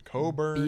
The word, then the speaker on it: Coburn Goss: Coburn or Coburn? – Coburn. – Coburn. – Coburn Goss. B as in boy.